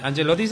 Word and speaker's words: Ancelotti: Ancelotti se pronuncia.